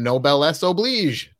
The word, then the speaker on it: Noblesse Oblige: It's Quinn from Noblesse Oblige. Did I say that right? Did I yes.